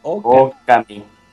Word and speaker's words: Okami: A ver, a ver, dile a la gente cómo se pronuncia Okami en realidad, güey. Es Okami. – Okami. ey. – Es O alargada y Kami. – Okami. – Como lo dicen al inicio del juego.